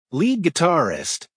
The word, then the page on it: lead guitarist: American English